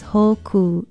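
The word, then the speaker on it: hōkū: Aloha, I'm Leilani Poliahu for Hawaii Public Radio, and our Hawaiian word of the day is hoku. It is spelled H-O-K-U with kahako or stress marks over both vowels.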